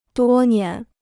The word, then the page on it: 多年: duō nián